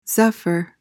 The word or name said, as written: zephyr